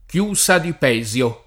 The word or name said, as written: Pesio